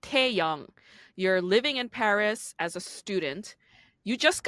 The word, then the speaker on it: Paris: even in France, they don't pronounce it Paris. They say Paris. They don't pronounce the S.